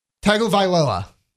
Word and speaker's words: Tagovailoa: Can you do me a favor and pronounce Tua's last name for me? – Tagovailoa. – Okay, yeah.